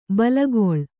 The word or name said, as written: ಬೇಲಿಕಳ್ಳಿ